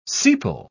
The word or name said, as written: sepal